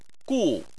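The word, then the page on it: gu: tone 4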